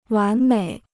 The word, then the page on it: 完美: wán měi